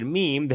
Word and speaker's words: meme: So he just details how he came up with the word meme, that he was trying to make rhyme with jeans and cream.